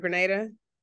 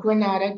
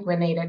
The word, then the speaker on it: Grenada: So from Grenada, did I get it right? Grenada? – Exactly. Not Grenada, Grenada, 10 points to you.